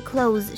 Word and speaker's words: cloth: 那这个字我们要注意哦，它不管是发音还是意思，都跟刚刚的那个 clothes 是不一样的。这个字念成 cloth，它的母音是发 r 的声音，而且它的尾音 th 是发无声的 s 的声音。